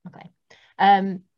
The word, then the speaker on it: MacLean: Like Alistair MacLean ones and things like that. Or MacLean, I can't remember how you say it. – Like John MacLean, like Die Hard. – Yeah, that's it, MacLean. Yeah, so I went from that.